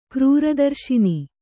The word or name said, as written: ಕ್ರೂರದರ್ಶಿನಿ